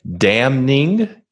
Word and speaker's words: damning: Two words I was interested in when you said TK was damning. You pronounced the N and softening, was it? You like you pronounce the letters that are usually not pronounced.